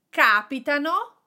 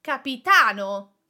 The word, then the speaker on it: capitano: Capitano, they happen, the accent is on the first A, and capitano, capitano, the accent is on the last A, and it means captain.